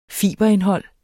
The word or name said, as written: fiberindhold